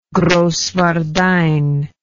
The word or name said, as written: Grosswardein